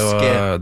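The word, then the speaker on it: Bosque: Ja, du må jo uttale en i Bosk faktisk, så det er Boske.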